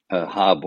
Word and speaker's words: Haab: There are 18 months of 20 days in the Mayan Hab, what we call the H-A-A-B is the way it's spelled, Hab calendar.